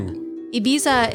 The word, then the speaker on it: Ibiza: Ibiza is an island right off the coast of Spain. – They actually pronounce it Ibiza.